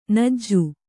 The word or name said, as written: ಞ